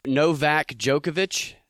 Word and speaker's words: Novak Djokovic: It's Novak Djokovic.